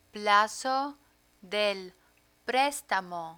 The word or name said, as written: plazo del prestamo